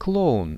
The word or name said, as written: kloun